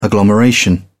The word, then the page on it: agglomeration: British English